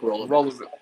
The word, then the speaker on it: Rolovich: And how do you pronounce his name? So I did the Rolovich. – That's right. Nick Rolovich.